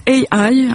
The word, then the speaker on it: AI: Oui, l'émission est baptisée le décompte AI. Alors, faut vraiment prononcer à l'anglaise, on me l'a confirmé.